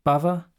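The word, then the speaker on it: bhava: I've also read in, in the liner notes to some of, one of your other albums that you're praised for your bava. Am I saying that correctly? Bava? – Yeah, bava means expression, expressive pain.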